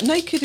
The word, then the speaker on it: NA-KD: For those that know, it's spelled N-A-K-D, and it's got a, what's that called? – Some kind of accent above the A.